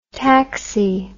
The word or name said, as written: taxy